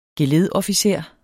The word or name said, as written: geledofficer